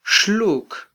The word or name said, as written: xluk